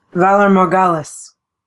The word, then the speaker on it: Valar Morghulis: I'm not going to be able to pronounce this. Valar Morghulis. – I think that's right. – Is that right? Okay, good. – Yeah, sounds right.